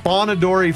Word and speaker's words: Bon Odori: It's the 2018, and Yuhito, you tell me if I'm saying this right, Bon Odori Festival. Yes. Yes,